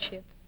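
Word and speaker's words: Hsu: Tell me now, your name, for instance, Shu, that's H-S-U, huh? – Yes. – Shu, that's pronounced. – Shu. I guess it's Shu.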